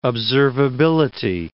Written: observability

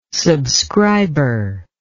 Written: subscriber

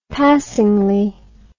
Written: passingly

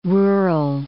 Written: rural

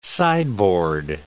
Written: sideboard

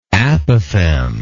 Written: apothegmatically

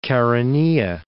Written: Chaeronea

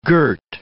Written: girt

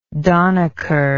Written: donicker

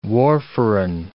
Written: warfarin

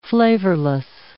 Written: flavorless

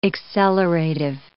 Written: accelerative

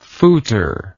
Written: fouter

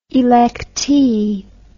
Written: electee

